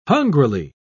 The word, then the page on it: hungrily: British English